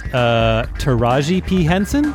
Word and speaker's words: Taraji P. Henson: Taraji P. Henson? – That's exactly right.